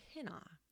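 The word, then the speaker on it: hina (ἵνα): In Greek, the word is pronounced hina, and in English, the word is translated either that or so that.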